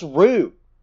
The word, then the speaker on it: Roux: R O U X. Nah, that's Rue. That's Rue, bro. That's how you pronounce that.